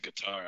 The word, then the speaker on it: Qatar: Cutter? How do you say Cutter? – I say Qatar.